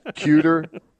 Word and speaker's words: Kueter: Cuter. K-U-E-T-E-R. Cuter.